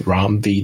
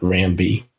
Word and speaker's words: Ram V: This issue was okay, but compared to the previous issues that Rom V, and I'll make sure we say it right, it's Rom V, not Ram V,